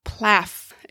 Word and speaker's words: PLAAFP: In 27 years of teaching, I've heard it called the PLOP, the PLEP, and finally the PLAAFP, which is a little hard to pronounce, if I'm honest. PLAAFF. It kind of sounds like an Aflac commercial.